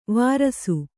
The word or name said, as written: ವಾರಸು